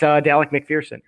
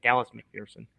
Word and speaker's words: Dallas McPherson: So that's Dallas McPherson. Oh my God, I butchered that. Dallas McPherson.